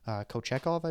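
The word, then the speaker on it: Kochetkov: Kochekov, I believe is how to pronounce his name. That's how you pronounce it.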